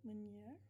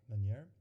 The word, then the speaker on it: Meunier: Can you pronounce that? Meunier? – Meunier. – Meunier.